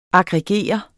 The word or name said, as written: aggregere